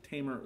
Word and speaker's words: Tamar: And anyways, Amnon was one of David's sons, and he got a crush on Tamar, or Tamar. There's several ways I've heard people pronounce it. I think Tamar is the proper way here.